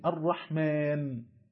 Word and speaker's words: الرحمن: فمثلا الرحمن تكتب ألف لام راء حاء ميم نون، على الرغم من أنها تنطق بألف بعد الميم الرحمن، ومع ذلك لا تكتب، وهذا أمر مشهور معروف في الإملاء للعربية، حيث تختصر هذه الألف أو تحذف اختصارا لكثرة ورودها والله أعلى وأعلم.